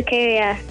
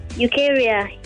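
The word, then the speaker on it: Ukeria: My name is Ukaria. Calling from. – Spell that for me. Quick. Rukaya, right? – Ukaria. U-K-E. – Uh-huh. – R-I-A. – R-I-A.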